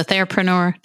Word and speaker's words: therapreneur: And I love how you have so many varied interests and how you frame things, because a therapreneur, did I say it right? – Yeah, you did.